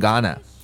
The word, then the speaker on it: gonna: 它读的感觉有点像 t t，所以 going to 啊 going to，当然这边有一个特别的地方，它会读成 gonna gonna。